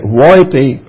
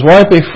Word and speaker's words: Woippy: The word is spelled W-O-I-P-P-Y, so I say Woypy, France is what I'm going to call it. It's closest I can get.